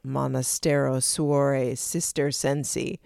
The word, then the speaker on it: Monastero Suore Cistercensi: I mean, few would say Monastero, Suore, Cistercensi. – Look at you crushing that pronunciation.